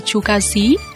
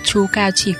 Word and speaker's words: Chu Cao Xí: Tên cũng phải đọc là Chu Cao Xí, mạnh mẽ, chứ không phải là Chu Cao chỉ có.